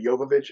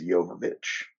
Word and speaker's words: Jovovich: Everybody loves when Mia Jovovich wakes up naked. We love her. I'm sorry. It's actually Jovovich. I've been saying it wrong for so long, I sometimes will slip into that. It's Jovovich.